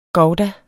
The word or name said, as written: gouda